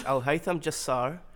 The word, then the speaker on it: Alhaitham Jassar: It's Alhaitham Jassar.